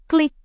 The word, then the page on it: klik: Indonesian